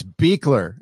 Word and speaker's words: Buechler: It's Buechler. It doesn't look like it. Yeah, it's ridiculous. We can't even decide within our family how to pronounce it, so don't feel bad.